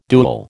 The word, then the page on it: duel: American English